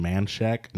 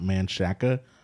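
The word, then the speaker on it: Manchaca: Shout out Valentina's in Manshack, not Manshaka, Texas, yes.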